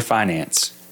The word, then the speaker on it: finance: Is it finance or finance? – It's finance.